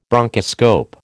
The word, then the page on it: bronchoscope: American English